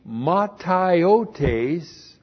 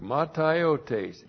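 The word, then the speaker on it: Mataiotes (ματαιότης): In the mataiotes of their mind. Looks like that in the Greek. Mataiotes. M-A-T-A-I-O-T. Long E-S. Mataiotes.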